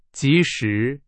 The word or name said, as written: 及时